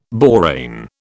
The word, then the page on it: borane: British English